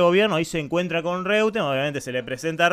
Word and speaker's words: Reutemann: Reutemann. Es la pronunciación de eh, original. Reutemann Reutemann.